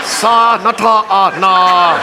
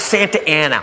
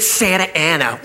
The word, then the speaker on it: Santa Ana: Sa-na-ta-na. How? It's Santa Ana. What says Santa Ana? Look, I know I didn't finish school, but that frickin' says Sa-na-ta-na. They pronounce it Santa Ana.